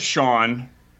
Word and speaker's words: Jon: And then later on in my year, because my name is Sean, but it's spelled J-O-N. – Right. – So it's like Jean.